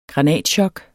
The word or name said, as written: granatchok